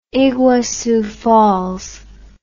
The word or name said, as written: iguassu falls